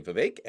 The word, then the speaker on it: Vivek: I was today years old when I discovered that the correct pronunciation is apparently Vivek, and obviously we will honor that on this show.